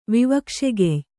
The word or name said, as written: ವಿವಕ್ಷೆಗೆಯ್